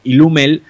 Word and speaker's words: Illume: Illumel. Eh, es un Ilume, se escribe I-L-L-U-M-E, o sea, Illume.